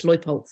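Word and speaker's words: Leupolz: I actually, when Melanie Leupoldt, is that how you say it, Melanie Leupoldt? My mum's a German teacher and she said, yeah, it's Leupoldt.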